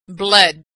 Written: bled

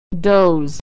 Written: doze

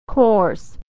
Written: coarse

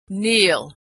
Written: kneel

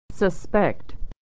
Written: to suspect

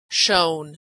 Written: shown

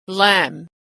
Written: lamb

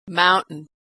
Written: mountain

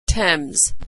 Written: Thames

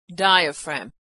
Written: diaphragm

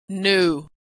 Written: knew

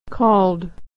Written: called

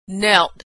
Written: knelt

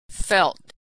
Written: felt

